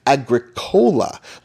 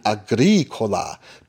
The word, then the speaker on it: Agricola: Rosenberg himself says there was a lot of people mispronouncing it Agricola, like it was a soft drink, instead of the proper Latin Agricola, to rhyme with Ricola.